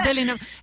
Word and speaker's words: Bellingham: Ya quedamos que es Bellingham.